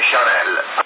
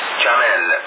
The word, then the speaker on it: Chanel: Io ho detto Chanel, e Gianni mi ha detto che non si dice Chanel, ma si dice Chanel, così, non mi ricordo com'era la cosa,